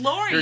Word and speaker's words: Lorian: Is it Lorian? Is that how it's pronounced? Because one of the main reasons why I don't go by my full name is because you don't know how to pronounce it? – I feel like nobody knows how to pronounce it. – Including you? – No, I know how, I say it's Lorian.